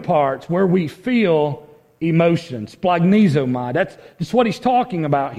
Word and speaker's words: Splagnizomai: Anybody want to try to pronounce the Greek word there? Splagnizomai. That's how you say that word. Splagnizomai.